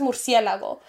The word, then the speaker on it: murciélago: And my showed it to my mom, and my mom fucking started laughing because she's like, first of all, she's not even pronouncing it right. Yeah. She's like, because my mom says murciélago, but she was saying murciégalo.